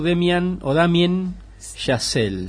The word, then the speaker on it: Damien Chazelle: Con Ryan Gosling y Emma Stone, dirigida por Damien Chazelle.